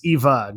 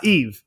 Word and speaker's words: EVE: I thought that was such a great moment because, yeah, that's how you know her character throughout the movie is Eva, not Eve.